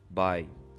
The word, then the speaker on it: by: Então, geralmente você tem, quando é escrito por alguém, você tem a preposição by. Não é bem é, é o mesmo de by de tchau, né, a pronúncia, mas o by seria por alguém, escrito por fulano, ok?